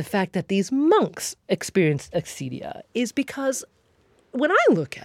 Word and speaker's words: acedia: Acedia.